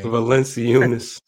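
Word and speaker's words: Valančiūnas: Valanciunas.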